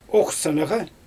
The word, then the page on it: oxsanaGa: Xyzyl